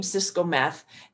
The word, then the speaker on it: Cisco-Meth: So the beautiful thing about my name, Cisco-Meth, is I am the only Cisco-Meth on social media.